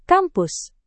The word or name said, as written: kampus